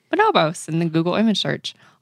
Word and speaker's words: Bonobos: Am I saying that right? Bonobos? Bonobos? – It's Bonobos. Okay. It's like how you say the monkey.